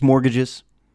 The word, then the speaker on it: mortgages: You don't pronounce the T. It's not mortgages, just mortgages.